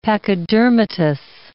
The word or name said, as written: pachydermatous